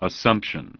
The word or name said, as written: Assumption